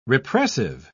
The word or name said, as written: repressive